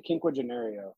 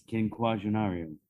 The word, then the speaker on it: Quinquagenario: It's quinquagenario. – Quinquagenario. – Kinfolk, qua, like the quan, gin, like the drink, ario, quinquagenario.